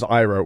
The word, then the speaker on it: Ira: Also, my name is Ira, which is spelled IRA.